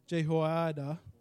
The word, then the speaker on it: Jehoiada: wherein Jehoiada," that's how you say it, "the priest, instructed him.